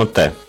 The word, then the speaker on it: MATE: Mate или Mate, я так и не понял, как его правильно произносить. – Ну, мы произносим Mate. – Ну, давайте будет Mate. 1.8.